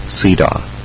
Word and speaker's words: CEDAW: This treaty is also known under a longer name, the Convention on the Elimination of All Forms of Discrimination Against Women, frequently abbreviated to CEDAW, pronounced CEDAW.